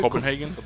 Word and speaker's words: Copenhagen: The Copenhagen, I don't know if that's how you pronounce it. – Copenhagen. – There you go.